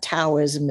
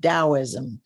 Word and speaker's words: Taoism: So Taoism, if you see the word tea as in tomato, A-O-I-S-M, that is Taoism. It's pronounced with a D as in dog, not a T as in tomato. It's not Taoism, it's Taoism.